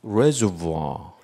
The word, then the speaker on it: reservoir: 근데 읽을 때 왜 이거를 reservoir 그리고 r을 안 읽을까요? 그 이유는 프랑스 말에서 왔기 때문이에요. 프랑스 사람들은 맨 마지막에 나온 자음을 읽지 않습니다. 그러니까 이것은 reservoir. 여러분이 프랑스에 가도 똑같이 이렇게 읽습니다. reservoir라고 읽습니다.